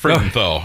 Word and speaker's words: Frudenthal: I hope I said that right. – Close enough, Frudenthal. Oh, that's, yeah, yep. Exactly what I said.